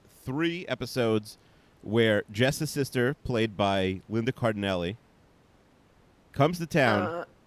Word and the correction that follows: Cardellini: It's Linda Cardellini. – Linda Cardellini.